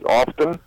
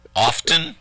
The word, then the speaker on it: often: Yeah, a couple of words that I don't like is often. – Often? – Often. – Pronouncing the T hard? – Yes. – Instead of often? – You know, yeah. How about soften and hasten and fasten?